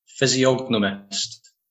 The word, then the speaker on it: physiognomist: Physiognomist.